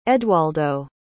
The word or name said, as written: edwaldo